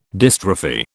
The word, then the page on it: dystrophy: British English